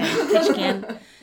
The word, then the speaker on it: Ketchikan: K-E-T-I-E. – Ketch. – Oh, T-C-H... K-E-N-A-N. – Yeah, K-E-T-C-H-I-K-E-N. – Ketchikan.